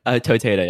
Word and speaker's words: Toteda: Toteda, yeah.